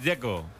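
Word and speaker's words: Džeko: El que me parece difícil es el de Checo, Dzeko. Porque DZ, ¿cómo se pronuncia? – Dzeko. Bruno dice que es Dzeko. – Como tirando a J, Dzeko o algo así.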